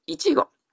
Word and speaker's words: 一期: 2 番、いちご。一学期の一期って書いて、いちごと発音するんですけども、これこそ我が茂きいちごの恥なり。これこそが、私茂樹の一生の生涯の恥でございます。